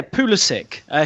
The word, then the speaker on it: Pulisic: First of all, Rob, we have to get started with Christian Pulisic. Did I say that right? He's been linked with Liverpool, the young Dortmund winger. What can you tell us about him? – Yeah, Pulisic.